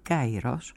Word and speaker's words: Καιρός: Την εβδομάδα που πέρασε δόθηκε στην 57χρονη Γερμανίδα συγγραφέα Τζένι Έρπενμπεκ το Διεθνές Βραβείο Μπούκερ 2024 για το μυθιστόρημά της «Καιρός» ή «Καϊρός», όπως διαβάζεται κατά την ερασμική προφορά.